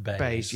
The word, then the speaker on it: BEIS: Apparently it's pronounced Bayes.